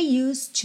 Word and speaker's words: used: O E de used tá pintadinho de cinza, ou seja, não é pronunciado. Você não vai dizer used to, used to. Vai do S direto pro D, que já vira T, para ligar no to.